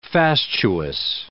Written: fastuous

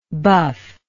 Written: buffable

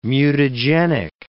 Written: mutagenic

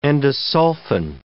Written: endosulfan

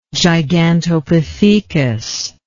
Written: gigantopithecus

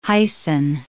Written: hyson

